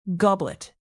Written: goblet

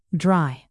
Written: dry

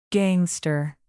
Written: gangster

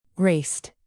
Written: raced

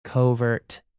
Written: covert

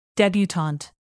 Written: debutante

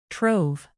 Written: trove

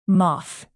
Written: moth

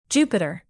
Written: jupiter

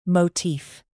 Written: motif

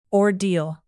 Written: ordeal

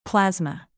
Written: plasma

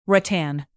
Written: rattan

